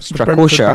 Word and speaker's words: Strakosha: I can do Stratoshka. I always get his name wrong. Strakosha.